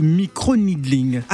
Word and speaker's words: micro-needling: D'accord, alors tu nous expliqueras les bienfaits et les inconvénients liés à cette pratique, mais tout d'abord, qu'est-ce que le micro-needling? – Alors, D'ailleurs, est-ce que je prononce bien? – Yes, you speak it very well.